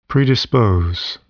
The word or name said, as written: predispose